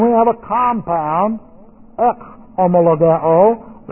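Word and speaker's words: homologao (ὁμολογέω): H, the rough breathing here, we give this an H-O sound, ho, and then M-O, and then L-O-G-E, long O, homologao, homologao.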